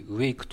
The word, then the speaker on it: WAIC: はい、えー、 WAIC と書いて WAKE と読みます。